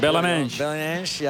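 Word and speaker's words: Belenenses: Haar vader speelde nog bij Bellenance. Zo spreek je dat uit. – Bellenance.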